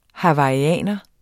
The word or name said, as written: hawaiianer